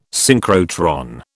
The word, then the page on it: synchrotron: British English